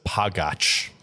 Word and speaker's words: Pagac: so we always tell them it's Joe Pagac, like magic. – Yeah, that's the way to do it here in America. I just got back from finding some of my old relatives in Slovakia, and they pronounce it Pagac. It's a pastry over there.